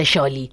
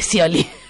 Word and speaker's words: Scioli: Si sabes que no se dice Yoli, se dice Scioli.